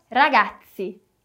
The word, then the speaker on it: ragazzi: Então, veja a palavra ragazzi, que pode ser meninos ou galera, dependendo do contexto. Vamos fazer uma pronúncia de TS aqui também. Ragazzi, ragazzi.